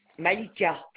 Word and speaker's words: Malika: C-H-A-L-O-P-I-N. – Chalopin. Votre prénom, s'il vous plaît? – Malika, M-A-L-I-K-A, à ma mère.